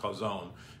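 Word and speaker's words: chazon (חזון): It's spelled C-H-A-Z-O-W-A-N, but it's pronounced chazon, meaning spiritual or mental sight.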